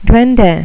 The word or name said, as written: duende